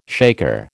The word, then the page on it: shaker: American English